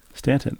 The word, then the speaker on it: Staunton: Stanton, like Giancarlo Stanton. – Right. – But spelled like Staunton.